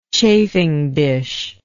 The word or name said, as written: chafing dish